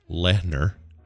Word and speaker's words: Lehner: Or Lehner, yeah. – Lehner. Yeah, the H. – It's like Lehrer, McNeil Lehrer. – Oh, yeah. – You don't pronounce the H.